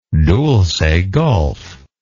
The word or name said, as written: Dulce Gulf